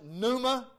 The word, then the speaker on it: pneuma: The word spirit, the Spirit of God is a Greek word that I pronounce it pneuma.